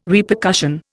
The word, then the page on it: Repercussion: Indian